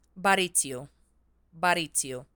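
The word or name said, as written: Barițiu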